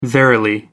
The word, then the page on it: verily: American English